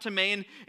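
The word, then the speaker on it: timae (τιμή): It's spelled like time, but it's pronounced timae, and it means to value.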